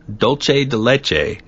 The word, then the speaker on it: Dulce de Leche: In 2001, Dulce de Leche. Did I say that right? – Yeah, Dulce de Leche is close enough.